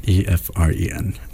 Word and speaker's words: Efren: So it's EFFRIN. – EFREN. – Okay, you spell it for us. – So my name is EFRENESPINOZA.